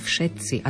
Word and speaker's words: všetci: Problematické slovo je všetci a tak často ho máme v lekciách používané. F je na začiatku, čiže nie všetci, ale všetci.